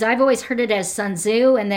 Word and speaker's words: Sun Tzu: Um, I was just listening to a video that talked about that the actual pronunciation doesn't use the U for Sun Tzu. It's Sun Z like T Z together, so Sun Tzu.